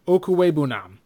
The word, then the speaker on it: Okwuegbunam: That would be tight end is Albert Okwuegbunam that's how you pronounce his last name.